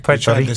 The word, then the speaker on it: Puerto Rico: I like how you said that. You tried to, Puerto Rico. – To say it like a Puerto Rican.